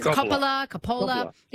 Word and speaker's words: Coppola: Coppola, I think you pronounce his. Coppola. Yep, is correct.